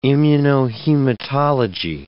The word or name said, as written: immunohematology